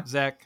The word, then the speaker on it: Zek: Yeah, Zek.